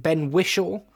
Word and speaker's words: Ben Whishaw: Is it Ben Whishaw? – Yes.